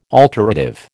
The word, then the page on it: alterative: American English